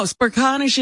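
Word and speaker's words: Smerconish: CNN's Michael Smirkanish. I'm embarrassed. I'm not a big CNN guy, so I'm probably saying that wrong. – No, Smirkanish is right.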